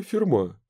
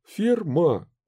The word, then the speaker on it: Ферма: «Вам говорит что-нибудь имя Ферма? Ферма. Ударение на последнем слоге».